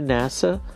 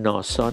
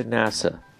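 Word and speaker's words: NASA: ناسا، تلفظش هم ناسا نیست، چون اصلا این یه چیز آمریکاییه و این دیگه نه از فرانسه گرفته شده، نه از بلغاری، نه از ژاپنی، این در ایالات متحده به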